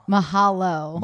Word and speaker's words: mahalo: Mahalo. – Mahalo. – He was just like walking around all week. Mahalo. – Mahalo. Mahalo at your boy.